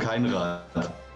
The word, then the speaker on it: Keinrad: Carlo Keinrad.